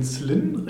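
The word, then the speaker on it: Zlín: Du bist 1979 in Zlin, richtig ausgesprochen, mhm.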